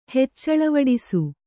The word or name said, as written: ಹೆಚ್ಚಳವಡಿಸು